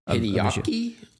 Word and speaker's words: Hideaki: Hideo Anno, I think is his name. I'm probably mispronouncing it, but he's like the main showrunner. – Hideaki Anno? Hideaki. Yeah, like there we go.